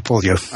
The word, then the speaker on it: Pulyu: It is Pulyu, like push me, Pulyu.